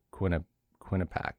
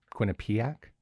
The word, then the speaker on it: Quinnipiac: I would have thought it was Quinnipiac. It's Quinnipiac? – Yes.